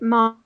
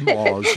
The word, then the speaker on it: Maz: I helped bring Maz to life, or should I pronounce it Mars? Maz, I'm very sorry. I think you said Maz.